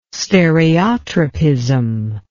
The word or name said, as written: stereotropism